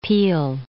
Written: Peele

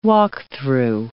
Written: walk-through

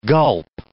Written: gulp

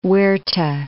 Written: Huerta

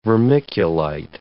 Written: vermiculite

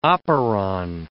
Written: operon

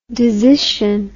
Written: desition

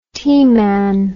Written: teaman

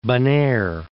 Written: Bonaire

